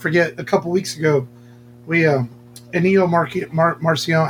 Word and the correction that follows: Ennio Morricone: how do you say it? Ennio, my voice is. – Ennio Morricone. – Well, there we go.